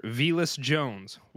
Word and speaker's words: Velus Jones: So to kick it off, I'm going to butcher this name, so be prepared. I have Mr. Velus Jones, wide receiver to the Bears. Initial thoughts there, Tony. – Uh, I think you did a good job saying his name.